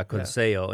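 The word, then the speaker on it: Conceição: Yeah, against I can't pronounce his name. It's C. – Conceo? – Yeah, Conceo.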